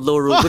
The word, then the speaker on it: Lorule: So and I don't know how exactly they're not low rule, and technically low rule is a parallel universe in a link between worlds. – Wait, is it really? – Yeah, it's called low rule without the W.